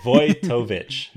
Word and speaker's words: Vojtovich: We would like to thank Matthew Votovich. – No, Agile Ventures. Oh, hold on. – Oh, no, okay. Vojtovich. Matthew Vojtovich. – We need to apologize. We've been saying Matthew's name wrong for like 2 years.